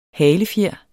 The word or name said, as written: halefjer